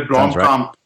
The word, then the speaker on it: Blomkamp: Neil Blomkamp? – Neil Blomkamp. You've got to say it with a South African accent.